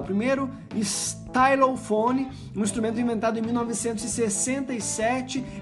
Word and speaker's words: stylophone: Eu não sei exatamente como pronuncia, como se pronuncia, mas presumo que seja stylophone. Isso mesmo, stylophone. Uh, Escreve-se S-T-Y-L-O-P-H-O-N-E.